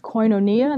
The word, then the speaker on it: koinonia: Communion is pronounced koinonia in the original Greek text.